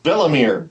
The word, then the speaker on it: Valamir: Your mistress has taken up with Valamir. That's what's become of your mistress. – Who is Valamir? – Did I get his name wrong? – No, actually, you got it right.